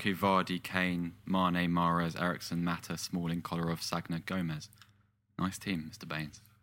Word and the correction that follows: Sagna: Sanya. – Yeah, you pronounced the G. You know, you said Sagna.